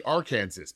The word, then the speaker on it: Arkansas: Pronounce K-A-N-S-A-S. – No. – It's Kansas. Pronounce A-R-K-A-N-S-A-S. It should be Arkansas, but we pronounced it Arkansas.